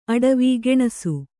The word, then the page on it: ಅಟವಿ: aṭavi